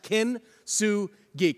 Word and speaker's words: kintsugi: It's pronounced kintsugi.